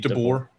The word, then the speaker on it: DeBoer: Pete DeBoer.